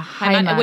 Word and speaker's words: Heimat: Heimat.